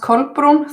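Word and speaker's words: Kolbrún: So it's pronounced Kolbrun Thora Löve.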